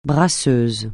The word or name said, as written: brasseuse